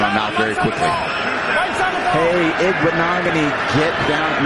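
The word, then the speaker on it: Igbinoghene: He couldn't even get it out the first two tries, and then he just nailed it. Igbinogany. – Well, what you had was a producer. – He had a producer in his ear pronouncing it properly.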